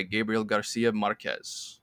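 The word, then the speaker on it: Gabriel García Márquez: This is from her native Colombia, 100 Years of Solitude by Gabriel Garcia Marquez. I hope I'm pronouncing that perfectly. – Yeah. And yes, you are.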